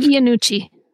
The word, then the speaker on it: Iannucci: It's Armando Anucci, I believe. Iannucci. Iannucci. Um, he's known for having his actors improvise their lines, which is uh, considering how intense this script is.